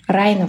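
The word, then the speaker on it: Rhino: как правильно говорить, Рина или Райна? Райна, вообще-то. – Я говорю Райна, а все наши... – Все трушные чуваки говорят Рина. Мой препод говорил Райна. Райна.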